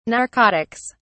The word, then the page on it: narcotics: British English